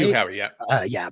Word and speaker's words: Hugh Howey: Notably, this show is an adaptation of a book written by Hugh Howey, is his name, right? – Hugh Howey, I'm pronouncing that right.